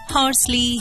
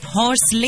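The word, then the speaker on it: Horsley: अच्छा, वैसे आम बोलचाल में इस जगह को ना हॉर्सले हिल्स भी कह दिया जाता है, लेकिन अगर इंग्लिश में नाम की स्पेलिंग और कलेक्टर साहब के नाम पर गौर करें तो सही प्रोनंसिएशन होगा यानी सही उच्चारण होगा हॉर्सली।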